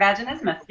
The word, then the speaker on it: vaginismus: This concerns a condition called vaginismus. Am I saying that right? All right, cool. – You are vaginismus.